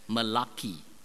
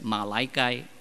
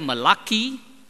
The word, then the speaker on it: Malachi: Now, some people would like to pronounce that book as Malachi, right? But don't pronounce it as Malachi. It's either Malachi or Malachi, all right?